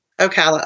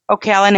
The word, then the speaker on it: Ocala: And that one is in the Ocala National Forest. Did I say that right? – Ocala. – Ocala National Forest.